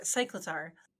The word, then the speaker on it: Cyclizar: The official Nintendo pronunciation is going to be Cyclizar. So Cyclizar.